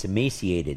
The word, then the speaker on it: emaciated: I didn't pronounce the word right. It's emaciated. Excuse me.